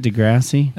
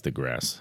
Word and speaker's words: deGrasse: Is that how you say it, deGrasse? – I think it's deGrasse.